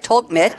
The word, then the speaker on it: Tolkmit: Ralph gave birth with his former VMS colleagues Andreas Milky and and Gunther Tomkit, I hope I pronounced that right, Tolkmit, they formed TRUFA, short for True Finance Applications.